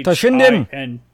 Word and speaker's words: Toshinden: Battle Arena Toshiden. Toshiden. Something. It's fun. – Toshinden. – I put it in the chat. I don't know how to pronounce it. T-O-S-H-I-N. – Toshinden.